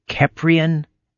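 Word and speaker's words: Kheprian: So you belong, I hope I'm pronouncing this correctly, you belong to the Keprian vampire tradition? – Yeah, Keprian, you've got it correct. And a lot of people pronounce it wrong.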